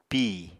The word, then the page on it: pìi: apatani